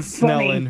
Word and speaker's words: Snellen: It's named after Herman Snellen, who's a Dutch ophthalmologist.